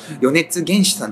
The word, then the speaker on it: 米津玄師: 米津玄師さんって読むんですかね。